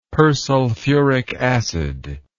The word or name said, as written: persulfuric acid